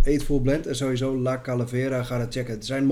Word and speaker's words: Calavera: Wacht, ja, La Calavera is Calaveras, Spanish pronounced. Calavera.